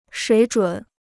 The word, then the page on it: 水准: shuǐ zhǔn